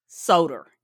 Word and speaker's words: Soder: Dan Soder. Did I pronounce that right? – Yeah. Wow. – I was like, it rhymes with soda.